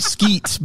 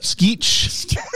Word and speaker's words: Skeech: Not Skeets, but Skeech. Not Skeet Ulrich. – Conventional spelling of timber? – Yeah. It's Skeech, S-K-E-E-C-H, Timber, as you would spell it.